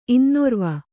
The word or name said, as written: ಇನ್ನೊರ್ವ